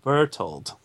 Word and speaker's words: Bertholdt: Anyways, now that we're all done with that, basically he keeps failing, and eventually he turns to Reiner and Brunholt, which I'm sure I'm pronouncing the name wrong. – I think it's Bertholdt. – Reinholt. – Or Bertholdt. – Bertholdt, or something like that. – Bertholdt? – It's Reiner and Bertholdt.